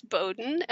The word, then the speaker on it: Bowden: So it's Zan, and my last name is Bowden, and that's spelled B-O-W-D-E-N. And I always tell people it's bow like what little girls wear in their hair, and den like where a bear lives.